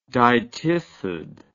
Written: dytiscid

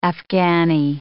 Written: Afghani

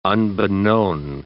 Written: unbeknown

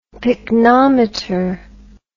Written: picnometer

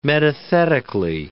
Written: metathetically

